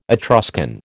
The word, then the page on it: Etruscan: American English